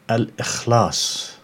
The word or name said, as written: Al-Ikhlas